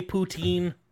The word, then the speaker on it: poutine: Putin. – Yes. Putin. Now they do. They do make a Putin. – Putin. – Putin. – Which would you rather I pronounce it the American way, poutine? – I would.